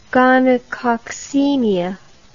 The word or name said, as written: gonococcemia